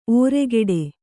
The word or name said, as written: ಒರ್ಗೊಡೆ